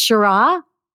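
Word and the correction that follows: Sharjah: Oh, yes, the Sarja. Yeah, it's Sarja. Sarja? Mm-hmm. Sarja Performing Arts Academy in Dubai and on the Artistic Advisory Board for North Texas Performing Arts.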